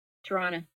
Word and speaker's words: Toronto: Toronto on, wait, how do you say Toronto? – Toronto.